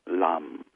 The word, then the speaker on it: lam: The traditional Sanskrit sound for the root chakra is lam, L-A-M, lam. It's really nice to enunciate the L sound a lot.